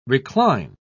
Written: recline

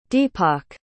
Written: deepak

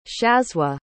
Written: shazzwa

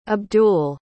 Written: abdul

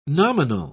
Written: nominal

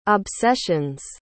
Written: obsessions

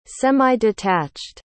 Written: semi-detached

